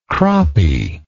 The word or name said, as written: croppie